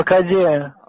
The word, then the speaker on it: коде: В генетическом коде. – Зарабатывайте деньги. – У меня здоровье. Да зачем мне? – Нет слова коде. Есть слово в генетическом коде. Ударение правильно ставьте.